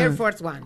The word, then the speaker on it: Air Force One: Al Air Force One, lo digo bien. – Air Force One.